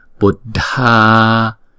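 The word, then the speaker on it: buddhānāṃ: Chữ dha là chúng ta đọc âm bật, chữ dh nè đọc âm bật, và chữ a có dấu gạch ngang chúng ta phải đọc hai nhịp, Buddhanam. Chữ nam này chúng ta phải đọc kéo dài chữ a hai nhịp, không được đọc là năm, đừng đọc là Buddhanam, Buddhanam, nam, phải không?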